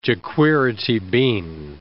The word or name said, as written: jequirity bean